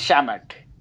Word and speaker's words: Shamet: I mean, I think, and by the way, do you pronounce it as Shamet or Shamet or how does he go? – I think it's Shamet. Yeah, okay. – I think it is Shamet.